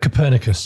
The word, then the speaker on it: Copernicus: How do you pronounce that? – Copernicus. – Copernicus.